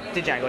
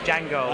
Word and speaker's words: Django: No, it's not Django. It's Django. – I know. – The D is silent.